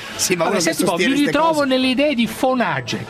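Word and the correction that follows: von Hayek: Semmai è Fonajek. È Fonajek. – Sì, sì, hai ragione, Fonajek.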